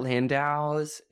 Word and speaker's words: Landau: Juliet Landau's, is that how I pronounce her last name? Landau? Whatever. – Yes, baby. Sorry.